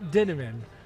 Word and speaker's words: Dinneman: Matt Dinneman and his beautiful book. Did I say that correctly? – You said it correctly. Matt Dinneman rhymes with cinnamon.